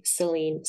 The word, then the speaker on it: Selene: It's Celine, C-X-L-I-V. My name starts with an S. I'll spell it for you so it's not Celine like Dion. S-E-L-E-N-E, Celine, C-X-L-I-V.